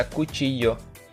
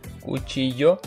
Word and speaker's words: cuchillo: Нож это кучиё. Пишется через двойную L. Кучиё. В конце И две Лки. О. Кучиё. Э, две Лки читается как Е.